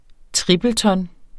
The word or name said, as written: tripleton